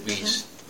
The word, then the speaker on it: Weiss: Well, if you take the near-term book by Tom G. Weiss, or Weiss, he pronounces it Weiss, but he spells it W-E-I-S-S.